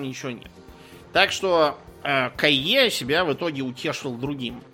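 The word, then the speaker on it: Caillié: Сделав правильные выводы, э, следующим туда отправился француз по фамилии Кайе. У нас почему-то везде пишется Колье, но он именно Кайе.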